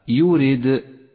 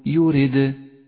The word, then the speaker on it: يريد: وإياك أن تتبع هذه القلقلة بهمز فهو خطأ شائع أي هكذا يريد هذا خطأ صوابه أن تقول يريد